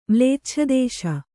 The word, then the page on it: ಮ್ಲೇಚ್ಛದೇಶ: kannada